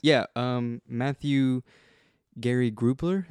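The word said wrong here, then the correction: Gubler: Is that his? – Yes. – Did I pronounce his name? – Gray Goobler.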